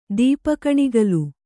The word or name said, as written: ದೀಪಕಣಿಗಲು